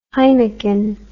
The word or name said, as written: heineken